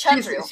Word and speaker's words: Chandru: It's Chandru. – She's Chandru. Yeah. So yeah. The D is it's a DH. So it's a the sound.